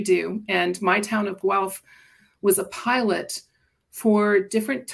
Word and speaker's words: Guelph: So born and raised in Guelph, Ontario, is that right? – That is correct.